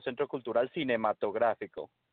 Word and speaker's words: Centro Cultural Cinematográfico: Technically, the name is in Spanish, so Centro Cultural Cinematográfico.